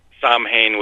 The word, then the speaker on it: Samhain: And Samhain would be associated with autumn or fall.